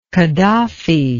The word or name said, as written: Qadhafi